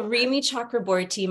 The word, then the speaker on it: Rimi Chakraborty: So Rimi Chakraborty, my family's from India.